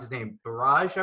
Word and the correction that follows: Ballage: I want to say? – Kalen Ballage, yeah.